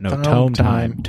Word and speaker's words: Tome Time: No, tome time. Tome time. T-O-M-E. – That's what I said, team time. – T-I-M-E.